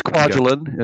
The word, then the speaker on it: Kwajalein: But I don't think it's giving anything away to say that the book largely revolves around Kwajalein. I'm saying that right? Kwajalein? – Yeah, it's Kwajalein.